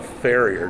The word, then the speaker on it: farrier: So, Doug, I noticed that you're a farrier.